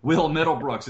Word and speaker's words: Will Middlebrooks: I always say Mil Wittlebrooks. I always say his name wrong. Will Middlebrooks is supposed to be back by this weekend.